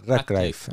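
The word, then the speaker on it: Ratcliffe: Radcliffe.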